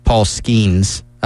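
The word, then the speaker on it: Paul Skenes: And Wes and I were going over some of the stats for their pitcher, Paul Skeens. I hope I didn't mispronounce that. – That's correct.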